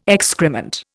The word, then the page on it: excrement: Indian